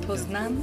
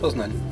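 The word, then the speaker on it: Poznań: I thought you pronounce it Poznan. Yeah. – Poznan. – Yeah, okay. Yeah. Posen, say the German word.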